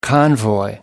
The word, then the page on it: convoy: American English